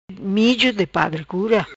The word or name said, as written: Mijo di padre cura